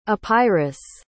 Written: epirus

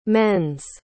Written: men's

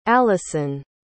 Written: alyson

